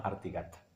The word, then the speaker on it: Artigat: que se llama Artigat. Pronuncia así porque es como se pronuncia en occitano. O sea, todo esto es una. No, ahí en francés sería Artigat, pero es Artigat.